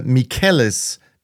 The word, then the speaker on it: Michele: I pronounced uh, Michele's name incorrectly. I said Michelle because that's what it looked like uh, to a dyslexic, but he is a he, and therefore I should have pronounced it differently. So I hope I've corrected it now by um, by naming him Michele Mazzoleni.